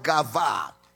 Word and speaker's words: gava: But in the Greek, in the scriptures, the Hebrew, is actually M-U-D-T-H, mooth. All right? Then the second word for death is the word gava, G-A-V-A, gava. It means to expire. It means to expire, gava.